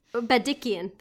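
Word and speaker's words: Bagdikian: I would say Bob Odenkirk as Ben, I'm going to pronounce this wrong, Badikian? Badikian. I was originally, Badikian, there we go.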